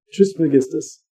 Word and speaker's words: Trismegistus: Trismegistus.